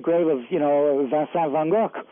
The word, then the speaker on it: Van Gogh: And I stayed there, and I went, later on, I went to Paris to visit Van Gogh's grave. – Mm-hmm. – Van Gogh, actually, is the proper pronunciation.